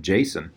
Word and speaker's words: Jacen: I'm pretty sure it's just Jaina and Jacen.